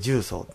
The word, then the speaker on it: 十三: 十三と書いて十三って読む。